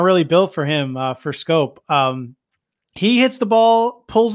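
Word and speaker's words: Schoop: Batting fifth for the Tigers is Jonathan Shoop. Scope. I don't know how to say his name. Never will. What is it? What is it, Mike? – Scope. – I say Scope. It's Scope. Don't say Shoop.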